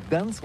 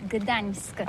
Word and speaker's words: Gdańsk: Oui, c'est pas très facile pour des étrangers, mais remarque bien que la langue polonaise, c'est la langue où on prononce chaque lettre qu'on écrit. C'est pourquoi on dit Gdansk.